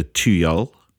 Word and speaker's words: Tuyul: In Indonesia, it is called the Tuyul. That's T-U-Y-U-L. I think that's pretty close to a pronunciation.